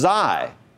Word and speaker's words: Xi: And we skipped the next one, which is pronounced Xi, like xylophone.